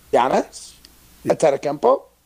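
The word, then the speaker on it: Giannis Antetokounmpo: Giannis Stetskempo. – That was a lot better. Yeah, you said it right that time.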